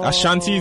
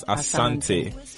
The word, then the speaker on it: Asante: Ashanti is the way the British pronounce my middle name, but the pronunciation is Asante.